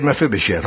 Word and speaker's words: Mephibosheth: Are you sure he said Mephibosheth? Pronounce it for me. Make sure you say it right. Mephibosheth? Yeah, he said Mephibosheth.